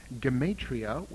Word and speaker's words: Gematria: Page 14 has the word Kabbalah, and on page 15 you will find Gematria, with a hard G, Notericon, and Temura.